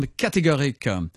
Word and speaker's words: KT Gorique: And we start off with our double CD album from KT Gorique. That's spelt with two letters, KT or two initials, uh, and Categorique, which works in French as uh, the same sound as categorical.